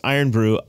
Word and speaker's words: Irn-Bru: But this is your unicorn mug, but I get to drink the Iron Brew in the, it's Iron Brew, I-R-N-B-R-U. Iron Brew. – That's exactly how they say it, by Brood the way, when you order it.